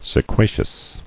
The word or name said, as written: Sequacity